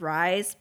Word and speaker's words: Rise: But because it's spelled R-I-S-E, everyone will say her name is Rise, but it is Rise.